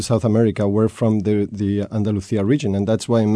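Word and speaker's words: Andalucía: And in Castilian dialect, it would be Andalusia. – Mm-hmm. – Barcelona. – Yeah, it's a different way of pronouncing the Buenos días. – Buenos días. – Okay, so there's these differences. – Yeah. – That's good to know, because I thought Andalusia was wrong, but it's only the Madrid way to pronounce it, Andalusia.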